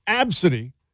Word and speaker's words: Abcde: But it's very confusing when you see it because Absidy is spelled A-B-C-D-E.